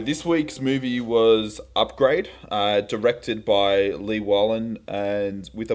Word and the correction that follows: Leigh Whannell: I never know how to pronounce his oh, name. Oh yeah, it's Leigh Whannell.